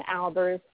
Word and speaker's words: Albers: And you can reach me on my Facebook at Bonnie Mosenter Albers. So I'll spell it for you. Bonnie is B-O-N-N-I-E. Mosenter is M-O-Z-E-N-T-E-R. And my last name, Albers, A-L-B-E-R-S.